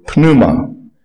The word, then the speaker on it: pneuma: And it is right there also in the more elegant Greek word pneuma, which that strange PN sound at the beginning, from which we derive, rather unspiritually, the pneumatic jackhammer that uses air to crush concrete.